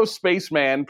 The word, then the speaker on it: Spaceman: And Leo Spaceman, pronounced Spaceman, is the best of the names.